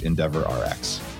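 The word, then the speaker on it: EndeavorRx: But if you did exist, you could use Endeavor RX. There, did I say that right? – You said it right.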